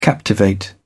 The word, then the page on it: captivate: British English